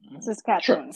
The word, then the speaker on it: Saskatchewan: All right, uh, here and over at uh, Canada, Saskatchewan, that how you pronounce it? Saskatchewan? – Saskatchewan. – Saskatchewan. Yes, yes.